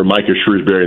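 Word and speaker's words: Micah Shrewsberry: 3-2-1. I think Notre Dame will be a tremendous program under Micah Shrewsbury in the future. Did I say it properly? Is that good? – Spot on.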